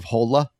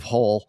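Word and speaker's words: Hole: It's, I think, I don't remember where it's from, Norway or someplace up there, but it's supposed to be pronounced Detective Hola, but it looks like Detective Hull,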